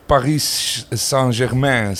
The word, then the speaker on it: Paris Saint-Germain: En nu is Paris Saint-Germain, zeg ik dat goed? – Ja, Bohemi.